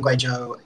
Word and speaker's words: Guizhou: What was it like growing up in Guizhou, which is southwest China, in case people aren't familiar? – So well done for pronouncing Guizhou, by the way. It's amazing.